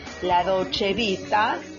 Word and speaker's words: La Dolce Vita: So, La Dolce Vita. La Dolce Vita. Italian pronunciation, of course,